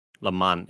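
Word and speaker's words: Le Mans: It's spelled like Lee and then Mans. – Yeah. – But it's Lamaze. Lamaze. It's kind of like that film festival that has one of those silent letters in it.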